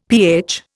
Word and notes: pH: Indian

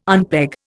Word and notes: unpeg: Indian

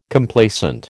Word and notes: Complacent: American English

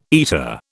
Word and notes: eater: British English

